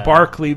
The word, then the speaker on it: Berkeley: It's actually spelled Berkeley, but it's pronounced Barkley because it's London.